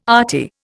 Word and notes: arty: Indian

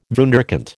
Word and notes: wunderkind: American English

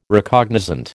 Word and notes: Recognizant: American English

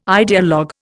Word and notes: ideologue: Indian

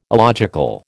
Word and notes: illogical: American English

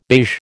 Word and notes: beige: American English

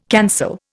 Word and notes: cancel: Indian

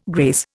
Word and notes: grace: Indian

